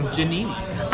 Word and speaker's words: Giannini: Giannini.